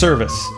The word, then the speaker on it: Servaes: It's pronounced Service, but it looks like Cervais. – Yeah. – Okay. It's Service.